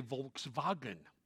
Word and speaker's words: Volkswagen: It's like asking a person who's dyed in the wool from German to say Volkswagen. They can't. They say Volkswagen. Why? That's the way it's supposed to be pronounced. Everybody from Germany knows that. That's the way you pronounce it.